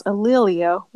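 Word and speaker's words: A'Lelia: Her daughter, which I believe is pronounced Alelia Walker, became the president of the Madam C.J.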